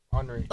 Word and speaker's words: Henry: Henry.